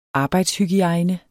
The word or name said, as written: arbejdshygiejne